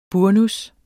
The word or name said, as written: burnus